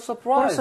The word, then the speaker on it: surprise: 실제로 R이 있기 때문에 surprise라고 발음되기도 하는데 대부분은 R을 제거를 하고 surprise라고 해버립니다.